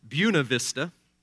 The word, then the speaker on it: Buena Vista: which the locals inexplicably pronounce as Buena Vista.